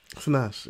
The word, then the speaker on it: Sinach: Sinach? Is that how you say her name? – Sinach, yeah.